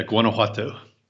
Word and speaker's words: Guanajuato: It's a city that you live in called, if I can pronounce this correctly, Guanajuato? – Yeah, that's it. You'll hear it pronounced a little differently here and there because it's an old indigenous word. It's not actually a Spanish word, but yeah, Guanajuato.